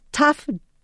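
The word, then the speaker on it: taf (תו): And uh, there's another explanation that the letter tav, which we'll pronounce taf, tet, uh, tav aleph vav, okay, means desire.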